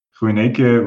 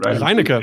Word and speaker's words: Reinecke: Das ist eben der Thorsten, aka Sune, und der Sebastian, der wie spricht man seinen, seinen Nickname aus? Ruineke, Reinecke? – Reinecke? – Reinecke. Ja, ja genau.